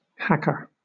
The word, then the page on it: hacker: British English